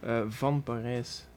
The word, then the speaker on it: Van Parijs: Van Parijs. It means from Paris. – Ah, ok. Mm. Avete sentito la pronuncia fiamminga, che non è nelle mie capacità, e significa che è proveniente da Parigi.